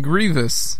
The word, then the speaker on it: Grievous: How do you pronounce it? – Grievous.